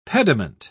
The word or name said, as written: pediment